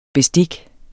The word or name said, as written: bestik